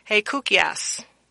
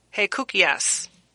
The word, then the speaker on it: hekousis: And my Greek word for you today is spelled H-E-K-O-U-S-I-S. Again, it's spelled H-E-K-O-U-S-I-S. And it's hey, give me a moment. Help me, Holy Spirit. Hey, Koukias. Hey, Koukias.